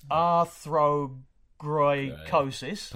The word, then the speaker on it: arthrogryposis: It's arthrogryposis.